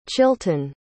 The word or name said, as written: chilton